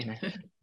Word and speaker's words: Minari: Christina, I'll come over to you, because I read in some interviews that you mentioned the personal connection that you have to Minari. Is that pronounced correctly? – Kind yeah. – Minari.